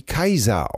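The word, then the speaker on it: Caesar: Ja, ich fand interessant einfach nur, danke für die Mail, dass Cäsar heute wie Kaiser ausgesprochen wird.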